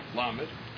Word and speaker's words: Lamed: In Isis de Rabbi Akiva, which is a very early work on the letters, it says that the word, the letter Lamed, which is pronounced Lamed, and which is spelled Lamed Mem Dalet, is an acronym for Lev Mevin Das, the heart that understands knowledge.